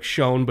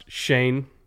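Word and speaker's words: Schoen: I know it's spelled like Shone, but it's pronounced Shane.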